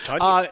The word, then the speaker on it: Tonyan: No, that's how you pronounce it. It's like Funyun. – Tonyan. – It's Tonyan.